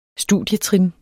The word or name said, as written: studietrin